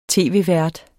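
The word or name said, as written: tv-vært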